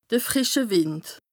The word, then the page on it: de frìsche Wìnd: Bas-Rhin; Strasbourg